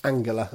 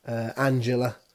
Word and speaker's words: Angela: Angela Merkel, pronounced Angela, of course, in German, but Angela, as far as spelt the same anyway, as an Angela as far as the song goes.